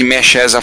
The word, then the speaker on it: MSX: Lembrar que Mexes é a forma como se fala soletrando MSX em espanhol, em SD é super deforme.